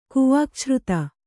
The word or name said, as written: ಕುವಾಕ್ಛ್ರುತ